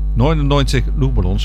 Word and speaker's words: 99 Luftballons: 99 Luftballons, zo moet ik het echt uitspreken in het Duits.